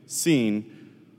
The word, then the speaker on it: Sin: I know it looks like sin in your Bible, but it's pronounced Sin.